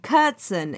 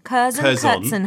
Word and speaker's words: Curzon: Curzon. How do you say it? – Curzon. Well, it depends how posh you are. Curzon would be the way that most of the posh people that go to it.